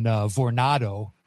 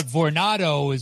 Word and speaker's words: Vornado: Well, it's like Coronado, right? That's how you pronounce it, isn't it?